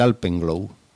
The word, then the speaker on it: Alpenglow: que se llama el Alpenglow. Nombre también bonito donde los haya. – ¿Cómo se escribe? – Pues Alpen. – Alpen. – Como Alpen en alemán, como si fuera a decir Alpes en alemán. – Sí. – Glow. – Globo. – G-L-O-W.